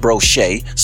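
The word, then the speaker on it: Brossette: my last name Brosette, better pronounced Brochet, some French shit, but we my motherfucking ass, nigga.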